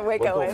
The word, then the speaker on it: Wacoans: what Cohens? Did I say it right? – Yeah, Wacoans.